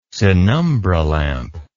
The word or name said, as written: sinumbra lamp